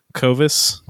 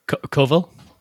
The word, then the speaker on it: Coville: How do you pronounce his last name? Clovis. – Coville.